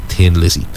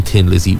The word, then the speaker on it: Thin Lizzy: The H in Thin Lizzy was deliberately added to mimic the word the way thin is pronounced in a Dublin accent, but ultimately the gag somewhat backfired because the band became known as Thin Lizzy rather than Tin Lizzy.